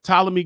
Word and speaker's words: Ptolemy: I was ready to say Ptolemy, but it's, the P is silent. All right. Ptolemy Gray.